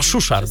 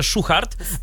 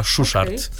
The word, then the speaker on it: Szuszart: Chyba Szuszart, wiesz? Ja myślałem właśnie też, że Szuchart, a ostatnio, czekaj, gdzie ja to chyba w Netflixie gdzieś słyszałem właśnie, jak... Pani lektorka mówiła Szuszart, także chyba tak się go wymawia.